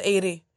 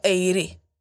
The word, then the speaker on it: Aire: Så fram till nu i mitt huvud, han heter fortfarande Eiri. Vi uttalar det precis som hon ja, har skrivit. – A-I-R-E. Alltså Eiri.